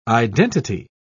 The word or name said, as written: identity